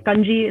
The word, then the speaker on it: congee: or as in East Asia, they call it congee. In South Asia, we pronounce it kanji.